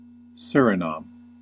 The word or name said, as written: Suriname